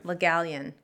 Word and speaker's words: Le Gallienne: Le Gallienne.